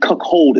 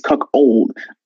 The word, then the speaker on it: cuckold: So it's not cuck hold. It's cuck old.